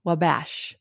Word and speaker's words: Wabash: Wabash. I knew I was pronouncing it wrong.